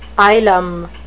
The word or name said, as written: ylem